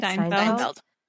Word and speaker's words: Steinfeld: Steinfeld.